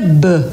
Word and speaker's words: Beux: A Júlia Beux, na verdade, é um sobrenome francês, B-E-U-X. Júlia B, para quem gosta de falar francês. Não é meu caso, tá? É porque ela me ensinou como é que fala.